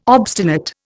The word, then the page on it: obstinate: Indian